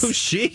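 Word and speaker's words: Kashyyyk: Kushik. – Yeah, that's how it says. How it's pronounced.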